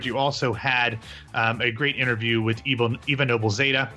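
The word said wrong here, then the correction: Noblezada: I mispronounced that name, Zada, and I shouldn't do that, especially because I've interviewed her before.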